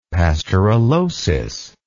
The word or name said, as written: pasteurellosis